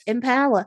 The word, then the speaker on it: Impala: I was thinking, have I forgotten how to say Impala? Because I put the emphasis on the L, and have I been saying it wrong all this time?